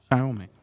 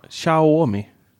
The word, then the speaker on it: Xiaomi: Uttalas det så? – Xiaomi? Ah. Xia, Xia, Xiaomi. Xiaomi. – Okay. – Xiaomi. – Mm. Man ska låta lite argare. så, eh, och där har vi ju sensorer, eh, så tempgivare i alla rum, i mm.